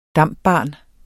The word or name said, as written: DAMP-barn